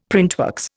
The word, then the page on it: printworks: Indian